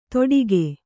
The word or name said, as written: ತೊಡಿಕೆ